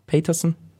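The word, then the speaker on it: Pejtersen: Mikke Bjerg und Johan Brise Pietersen, falls ich die richtig ausgesprochen habe. Petersen.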